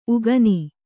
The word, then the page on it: ಉಗುನಿ: uguni